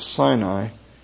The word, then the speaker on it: Sin: It's S-I-N in your English Bibles, but in Hebrew it's pronounced Sin, as in S-E-E-N, Sin.